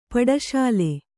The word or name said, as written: ಪಟಸಾಲೆ